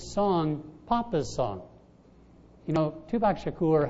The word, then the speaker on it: Tupac Shakur: Well, today's Father's Day, and I was reading some words of that great theologian, Tupac Shakur.